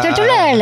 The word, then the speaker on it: Tortorella: Tortorella.